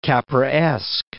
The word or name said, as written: Capraesque